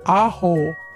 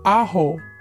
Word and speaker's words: ajo: To say garlic in Spanish, we say ajo. So repeat after me, amigos. Ajo. – Ajo. – Ajo. – Ajo. – Muy bien, amigos. And now it's time to go make some pan de ajo or garlic bread.